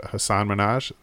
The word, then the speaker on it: Hasan Minhaj: I'm going to say his name wrong, but Hasan Minhaj. No, maybe I didn't say it wrong. – No, that's right.